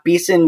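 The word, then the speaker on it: Behson: And my name is Scott Beeson, which is spelled weird. It's B-E-H-S-O-N.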